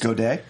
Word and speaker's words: Godey: This is based on originally a novel that was written by John Godey back in like 58, maybe. – It's pronounced Godet.